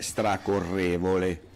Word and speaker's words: stracorrevole: Con l'accento sulla E, stracorrevole.